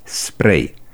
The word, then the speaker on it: spray: La cuvântul spray avem doar această pronunție, spray. Nu este corect să pronunțăm nici spray și nici spray. Cât despre efectele unui spray lacrimogen,